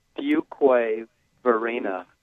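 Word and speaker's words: Fuquay-Varina: you got to tell me how to pronounce this place again where you live. – Unfortunate. Fuquay Varina. – All right, it is Fuquay.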